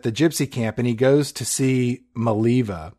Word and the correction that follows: Mulva: It's pronounced Mulva. – Lawrence goes to see... wait, it rhymes with what? Vulva? – That's right.